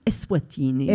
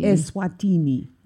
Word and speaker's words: Eswatini: How do you say that other one? Equatini? – Eswatini. – Eswatini.